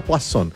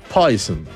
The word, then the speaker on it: Poison: Es como Poisson, nunca diga Poisson, Poison. Pero vamos con Foreigner, no vamos a ir con Poison, no.